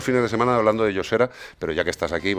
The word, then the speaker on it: Josera: ¿Cómo se dice, Zamorano? – Yosera.